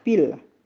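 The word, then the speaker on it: Pille: Nah, di bahasa Jerman, P atau Pile itu adalah istilah sehari-hari yang dipakai untuk olahraga sepak bola, pil. Ya, kalau tulisannya pile, tapi bacanya pil.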